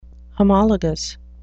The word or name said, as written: homologous